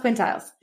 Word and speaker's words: quintiles: Also in February from Rick, we're going to be having the long awaited septiles and quintiles class. Am I saying that right? Septiles, quintiles.